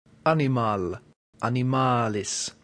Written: animal

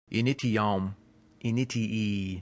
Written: initium

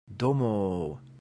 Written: domo

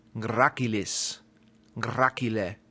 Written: gracilis

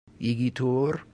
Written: igitur

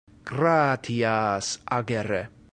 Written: gratias agere

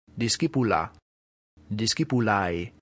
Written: discipula

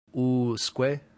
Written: usque

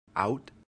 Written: aut

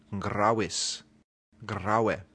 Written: gravis

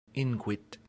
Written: inquit

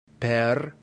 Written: per

